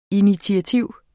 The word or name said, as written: INITIATIVERNES